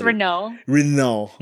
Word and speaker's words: Renault: It's Renault. – Mm-hmm. You see, I want to see the conclusion for Dungeons and Dragons. – With the Renault.